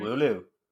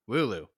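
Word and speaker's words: Wooloo: Wulit. Is that it? Wulit? Am I saying that right? – Wulu. – Wulu.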